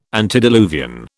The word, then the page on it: antediluvian: British English